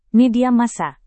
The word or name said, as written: media massa